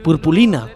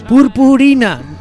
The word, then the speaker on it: purpurina: y yo aquí en mis 34 añazos diciendo purpulina delante de gente diciendo, no se dice purpulina, ¿qué dice purpulina? Sí, hombre, ¿no es purpulina? ¡Purpurina! Que no, coño, purpulina. Que no, de púrpura, coño.